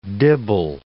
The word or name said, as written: dibble